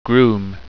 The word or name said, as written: grume